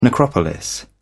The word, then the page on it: necropolis: British English